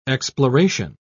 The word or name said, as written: exploration